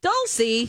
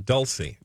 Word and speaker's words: Dulce: By the way, it looks like it's spelled like dulce de leche, mm-hmm. – but it's dulce. But it's pronounced dulce. – Dulcie. – At least the locals call it Dulcie.